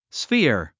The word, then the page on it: sphere: sfíə r; スフィヤ